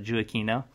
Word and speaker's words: Giacchino: It's Michael Giacchino, and spelled not like that, so I had to look up the pronunciation, but Giacchino.